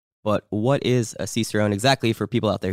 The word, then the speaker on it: Cicerone: Oh, and more importantly, certified Cicerone. Did I say that right? Uh, Cicerone, yeah.